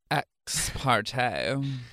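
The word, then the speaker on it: ex parte: Ex-parte.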